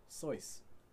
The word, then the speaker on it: Seuss: You've been pronouncing that last name wrong this whole time. – Seuss? – It's actually pronounced Seuss.